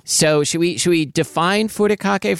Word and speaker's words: furikake: And that's because this week we're talking about furikake.